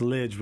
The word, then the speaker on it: Lij: Yeah, so the silly joke is that when people are trying to understand how to pronounce my name, I tell them it's Lij, which rhymes with fridge, because I'm cool like that, which is just about the dumbest joke in the world, but now you'll never forget.